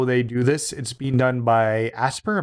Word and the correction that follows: Aspyr: Am I saying that right? – I think it's Aspyr.